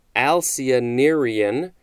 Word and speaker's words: Alcyonarian: And next, we have Alcyonarian, and I believe that is the correct pronunciation, A-L-C-Y-O-N-A-R-I-A-N.